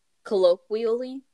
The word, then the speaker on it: colloquially: On Twitter, anti-hate group activist Sam Sachs pointed out that black Dr. Martens, colloquy? Did I say that right? – Colloquially? – The theory, love it.